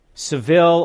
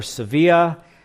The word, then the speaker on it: Sevilla: Seville or Sevilla, that's how you say it after you've done a vacation, right? You get the right pronunciation.